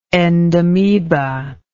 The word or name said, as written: endamoebic